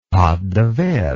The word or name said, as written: pate de verre